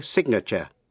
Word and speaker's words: signature: Âm G trong chữ sign câm, nhưng phát âm trong chữ signature, danh từ. Trong chữ sign, S-I-G-N, nghĩa là ký tên, âm G câm, không phát âm.